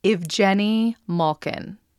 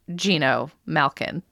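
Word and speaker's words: Evgeni Malkin: Evgeny Malkin. – In everywhere but Pittsburgh, that is the correct way to say it. – But in Pittsburgh, it's usually Gino Malkin.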